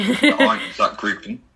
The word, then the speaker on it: Einsatzgruppen: Einsatzgruppen.